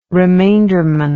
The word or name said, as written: remainderman